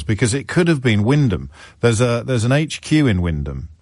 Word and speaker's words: Wymondham: Wyndham is one of those curious English towns that's spelt entirely differently from how it is pronounced. It's spelt Wymondham.